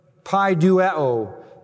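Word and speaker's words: Paideuo (παιδεύω): He uses the word paideuo, from which we get our English word pedagogy, which we use to refer to an instructor or a teacher. Paideuo is how you say it.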